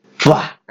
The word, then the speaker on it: Fuah: Pero pronunciado así. ¡Fua! Acaba de H. Pero con F. – Eso, es. pero como suena, F-U-A-H.